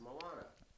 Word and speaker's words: Moana: There's a scene in Moana. Is that, am I saying it right? – Yeah, it's Moana.